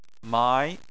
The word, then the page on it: mai: 2